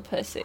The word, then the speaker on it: puszi: It's slightly. I mean, it's basically the same. It's just like Hungarian pronunciation, but it's spelled differently. It's spelled P-U-S-Z-I.